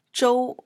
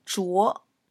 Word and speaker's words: Zhuo: It is often written Zhou, which does exist in Chinese, but it is pronounced Zhou versus Zhuo. So, in this case, the correct pronunciation and the correct spelling is Zhuo, Zhuo Ding Feng.